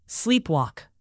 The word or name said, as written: sleepwalk